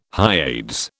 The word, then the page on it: Hyades: British English